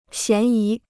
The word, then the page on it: 嫌疑: xián yí